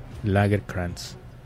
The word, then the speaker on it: Lagercrantz: David Lagercrantz. Algo así. Es que. – Pues nada más acuérdate de la cerveza Lager y ya. Lagercrantz.